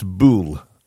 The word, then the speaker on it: Bulwell: She said, there's a small town called Bulwell. That's how it's spelled, all right, Bulwell. She said, but it's pronounced Bool by us locals.